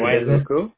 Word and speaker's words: Wailuku: Wailuku.